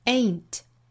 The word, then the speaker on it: ain't: 这个词里包含双元音 a 后面加前鼻音 n， ain't ain't ain't。那在这一段之中呢，后面都遇到了前鼻音 n 的发音，所以 ain't 尾部的 t 的发音都略掉。